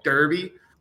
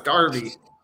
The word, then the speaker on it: Derby: You pronounced it right. – Yeah, what do you think I was going to say? Derby? – Yeah. – No, it's Derby. Derby.